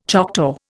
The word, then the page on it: Choctaw: Indian